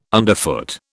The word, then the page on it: underfoot: British English